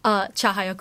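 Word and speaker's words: Cahayaku: So, okay, so the name of the song is my pronunciation might not be very good, but Kahayaku. – Chahayaku. – Chahayaku. C A is cha.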